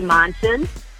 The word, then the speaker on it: Monson: And they can search for my profile by just keying in Mandy Monson. And my last name is spelled M-O-N-S-O-N.